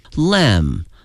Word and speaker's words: lamb: Lamb 它的拼写呢是 L-A-M-B、哎、它的拼写有一个 B，但是它的发音是没有 B 的，单词呢以 M-B 结尾的话呢，一般情况下这个 B 呢是不发音的。